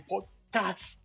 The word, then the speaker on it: podcast: Sí, como y bueno, de eso hablaremos más adelante en el podcast. Y mi sugestión era que lo llamaron Pocas, pero me dijeron que tenía que pronunciarlo bien como Podcast.